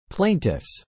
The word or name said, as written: plaintiffs